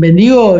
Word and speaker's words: Bendigo: Hablando de house Eating, ahora están en Bendigo, lo voy a decir en castellano, no sé si se pronuncia así. Eso es Nosotros le decimos Bendigo también. Ah, perfecto.